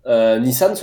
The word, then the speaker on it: Nissan: Après ça, par contre, tu vas avoir par exemple Nissan se prononce Nissan au Québec. Personne ne dit Nissan.